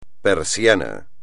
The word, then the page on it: persiana: espagnol